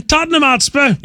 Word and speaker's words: Tottenham Hotspur: Tottenham Hotspur.